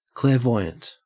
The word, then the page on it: clairvoyant: Australian English